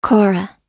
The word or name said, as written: kora